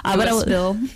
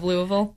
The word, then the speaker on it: Louisville: Louisville, yes. Instead of Louisville.